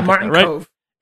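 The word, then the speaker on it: Martin Kove: It starred one Ralph Macchio, one Noriyuki Moriarty, I hope I pronounced that right, Elizabeth Schur, William Zabka, hell yeah, and Martin Cove, I think is how you pronounce that. Apologies. – Yeah, Martin Cove.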